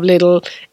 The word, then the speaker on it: Lidl: But everyone in the country says Lidl. – Yeah. I've never been sure. But look, you have it here, folks. You're getting an official, proper pronunciation of Lidl.